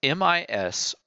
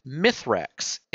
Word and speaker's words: Misraaks: He is called Misrax, Misraaks. That is his true name. But for whatever reason, we humans, guardians, whatever, English speaking beings, have always pronounced it Mithrax, Mithrax.